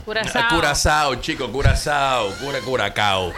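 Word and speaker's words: Curaçao: Óyeme, se escribe Curacao, pero se lee Curazao.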